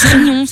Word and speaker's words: Riom: Il y a Rion, ça s'écrit R-I-O-M. – Et alors? – Et pourquoi vous faites ça? – Mais ça se prononce Rion.